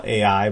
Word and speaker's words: Ai: Sometimes we call it Ai, but I is how I pronounce it, so you could say it either way, I guess.